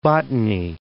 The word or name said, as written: Botany Bay